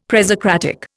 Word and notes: presocratic: Indian

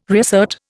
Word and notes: reassert: Indian

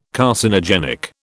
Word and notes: Carcinogenic: British English

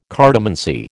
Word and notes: cartomancy: American English

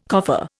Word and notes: coffer: Indian

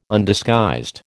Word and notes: Undisguised: American English

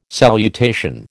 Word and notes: salutation: American English

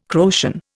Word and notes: Croatian: Indian

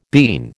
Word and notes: bean: American English

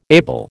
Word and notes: able: American English

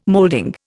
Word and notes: molding: Indian

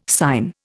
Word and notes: sign: Indian

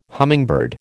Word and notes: hummingbird: American English

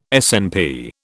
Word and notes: SNP: British English

